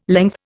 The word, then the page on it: length: Indian